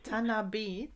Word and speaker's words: Tanabe: Tanabe. – Die Betonung ist auf dem E.